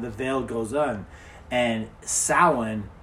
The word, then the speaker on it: Samhain: So Samhain, that's how it's spelled. – That's weird because... – But it's pronounced Samhain.